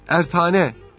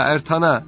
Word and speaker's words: Eratna: O zamanlar Kayseri, Sivas ve çevre vilayetlerde Ertane veya Ertana veya Erdane veya Eratna çeşitli okunuşları var, beyliği vardı.